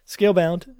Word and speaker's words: Scalebound: They also showed that other game, Scalebound. Am I saying it right? – Scalebound.